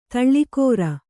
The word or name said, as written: ಧಾಳಿಕಾರ